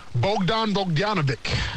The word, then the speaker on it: Bogdan Bogdanovic: Bogdan Bogdanovic, said it right.